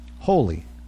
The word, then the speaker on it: wholly: Holy. Now, I know holy rhymes with holy.